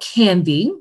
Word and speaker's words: Canviiy: So, so as I shared, the name of the company is called Canviiy, and I'm going to spell it because oftentimes people think I'm saying candy. so it's spelled C A N V I I Y.